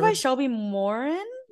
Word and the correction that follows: Mahurin: Shelby Mahurin.